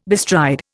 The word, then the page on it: bestride: Indian